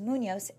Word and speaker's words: Munoz: And my last name is Munoz, MUNOZ.